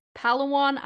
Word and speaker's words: Palawan: And they went to Dos Palms Resort off Palawan Island.